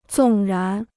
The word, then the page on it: 纵然: zòng rán